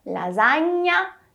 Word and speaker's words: lasagna: Ó, lasagna, lasagna, alla bolognese, a bolognese. O que muda, como eu já comentei, é só a escrita. Portanto, o GN em italiano será pronunciado como o nosso NH.